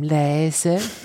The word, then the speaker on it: leise: Das Ei ist ein A und ein E, nicht ein A und ein I. Leise. Nur, das ist viel zu lange. Das leise gehört immer kurz ausgesprochen.